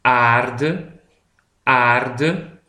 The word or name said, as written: de aarde